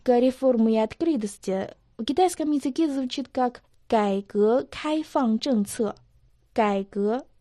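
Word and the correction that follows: Гайгэ (改革): «Кай» произносится в третьем тоне, «гэ» – во втором тоне. Слово «кайгэ» переводится как «реформа». – «Гайгэ» – реформа.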